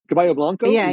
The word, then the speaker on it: Caballo Blanco: Am I saying it right? – Caballo Blanco? – Yeah, exactly. – Caballo Blanco.